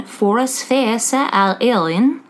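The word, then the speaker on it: Foras Feasa ar Éirinn: It's mentioned in Foras Feasa al Eilin by Geoffrey Keating. That's exactly how you say it.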